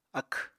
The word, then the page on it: akw: apatani